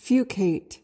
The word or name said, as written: fucate